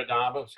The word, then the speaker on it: Adames: Is it Adames? Is that how he pronounces his name? – Yeah, Adames, yeah.